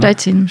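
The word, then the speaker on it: Szczecin: Мене викинули з автобуса, який потім їхав далі з Варшави на Щетін. Боже, я досі не можу вимовити Щечин нормально. – Щечин.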